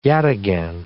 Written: yataghan

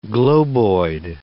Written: globoid

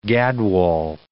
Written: gadwall

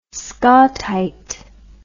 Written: scawtite

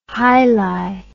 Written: hili

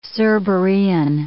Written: Cerberean

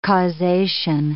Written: causation

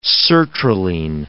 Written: sertraline

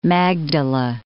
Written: Magdala

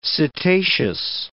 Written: setaceous

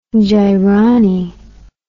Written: gironny

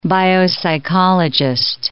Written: biopsychologist

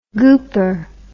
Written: gooper

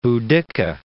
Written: Boudicca

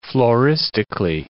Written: floristically

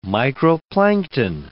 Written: microplankton

